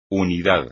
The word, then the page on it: unidad: espagnol